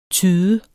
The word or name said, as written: tyde